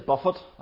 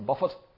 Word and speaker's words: Buffett: But one of the interesting things about Warren Buffett, it is Buffett. I thought for years it was Buffet, but no, it's Warren Buffett.